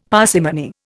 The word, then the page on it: parsimony: Indian